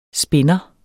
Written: SPINDERE

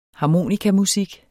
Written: harmonikamusik

